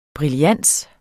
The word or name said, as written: brillans